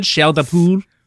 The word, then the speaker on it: chair de poule: Is my pronunciation good? Chair de poule. – Yeah, that's really good.